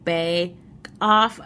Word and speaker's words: Baekoff: But we spell it B-A-E-K-O-F-F. So it's like Bay Off.